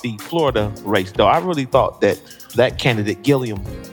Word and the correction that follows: Gillum: is I said it right, Gillum? – Gillum.